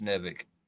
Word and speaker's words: NiveK: Is it Nivek or Nivek? – It's Nivek.